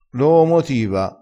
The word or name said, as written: loomotiva